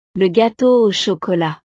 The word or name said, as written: le gateau au chocolat